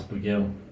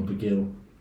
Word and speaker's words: Bugiel: That's Bugiel. – Yeah. Bugiel. – Bugiel, yeah, that's right. I mean, over the years, there's so many different ways of pronouncing it, but yeah, it's Omar Bugiel.